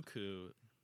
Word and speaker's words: Koekkoek: K-O-E-K-K-O-E-K. – Cuckoo. Cuckoo for Cocoa Puffs. – And I like how the last K is silent.